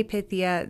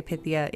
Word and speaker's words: Pythia: How do you say Pythia? That's how you say Pythia, by the way. Well, that's how you say Pythia in English. In ancient Greek, it would be Pythia.